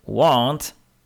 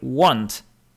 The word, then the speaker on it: want: Inny do tego bardzo podobny wyraz w wymowie to chcieć, to want, want, want. I want to get it. Chcę to dostać. I want to get it. Nie pomylcie więc. Won't, want, won't, want.